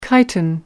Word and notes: chitin: American English

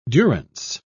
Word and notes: durance: British English

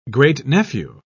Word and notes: great-nephew: British English

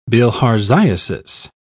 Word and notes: bilharziasis: British English

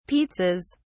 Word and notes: pizzas: British English

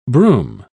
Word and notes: brume: British English

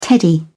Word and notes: teddy: British English

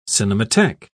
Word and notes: cinematheque: British English

